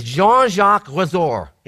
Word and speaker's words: Jean-Jacques Rousseau: I'm going to butcher this. His name is Jean-Jacques Rousseau. Is that close? Anyways, yeah, that's it. That's it. You got it.